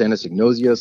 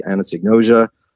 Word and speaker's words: anosognosia: It's sometimes pronounced anosognosia. Some people say anosognosia.